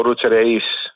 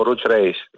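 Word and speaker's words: Oruç Reis: Δεν το κάνω για διόρθωση να κάνω τον έξυπνο, απλώς το λέω με την τουρκική προφορά. Γιατί έτσι είναι το όνομα, αλλά όχι ότι είναι λάθος όταν όλος ο κόσμος στην Ελλάδα τουλάχιστον το λέει ο Ρούτς Ρέις. Δεν είναι λάθος, δεν χάθηκε ο κόσμος.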